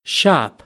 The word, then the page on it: shop: American English; ɑː